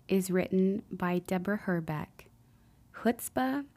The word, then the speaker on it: chutzpah: Growing up in a Jewish home, I've always loved the Yiddish word chutzpah, rhymes with footspah, and admired those who exhibited this quality.